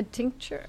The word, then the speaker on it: tincture: she had a toothache that she was actually using one of Mary's tincturns to fucking solve it. – What right? – the fuck is a tincturn? – A tincture or what? I don't know how you pronounce it. Tincture.